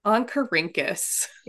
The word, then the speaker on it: Oncorhynchus: Can I click on this video of how to pronounce the scientific name real quick? – Yeah, please do. – Oncorhynchus. Oncorhynchus. – Oncorhynchus. – Yeah. There you go.